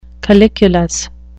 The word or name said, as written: calyculus